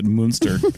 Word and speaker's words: Münster: And anytime the U has two dots over it, I have to pronounce it Münster. – I think anyway, that is how you're supposed to pronounce it. So. – I know, but like a little more emphasis on the moo.